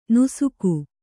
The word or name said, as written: ನಾಶಕ